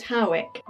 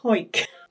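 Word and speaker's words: Hawick: Now, some people pronounce it Howick, but a quick Google has taught me that people from Hoik tend to call it Hoik.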